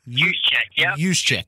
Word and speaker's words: Juszczyk: And then we got this fullback, Kyle, and I'm hoping I'm saying his name right, Juszczyk. – Juszczyk.